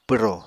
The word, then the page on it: pwro: Apatani